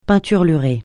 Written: peinturlurer

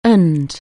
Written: ancillary

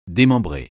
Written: démembrer

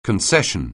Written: concession

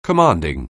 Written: commanding

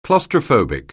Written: claustrophobic

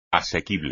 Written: asequible

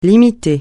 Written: limiter

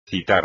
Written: citar

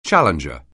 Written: challenger

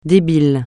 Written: débile

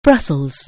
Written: Brussels

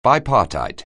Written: bipartite

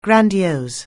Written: grandiose

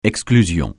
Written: exclusion

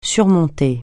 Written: surmonter

